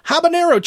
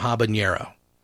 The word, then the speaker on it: habanero: And I even said on Facebook at one point, when did we drop the enye from habanero? – Habanero. – And I looked it up. There was never one. It was actually Americans mispronouncing habanero or habanero, assuming that the N would be an enye sound.